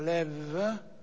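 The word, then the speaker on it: lev (לב): Vi ricordate che in ebraico la parola cuore si dice in tuo modi leb, si pronuncia lev, e lebab, che si pronuncia levav.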